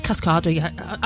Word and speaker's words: Cascada: It's Cascada.